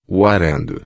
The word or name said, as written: arando